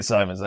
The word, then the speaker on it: Symons: I'll go to my grave not knowing how to pronounce it. And you're Welsh. It is Kit Symons.